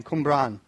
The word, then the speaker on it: Cwmbran: It's spelled C-W-M-B-A-B-R-A-N, Cwmbran.